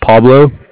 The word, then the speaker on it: Pablo: Yeah, so my name is Pablo, P-A-B-L-O, and I'm just calling about some equipment financing from the Bank of Cardiff.